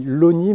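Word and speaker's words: Longny: Alors on dit Logny, même absolument. – Si ça s'écrit Logny. – Oui. Voilà. Mais il y a d'autres exemples dans les patronymes des où il y a des longs qui se disent l'eau.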